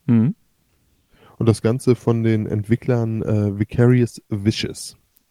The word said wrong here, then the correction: Vicarious Visions: Visions. Ja, Vicarious Visions. – Mhm. – Erstmal Erstmals richtig gesagt.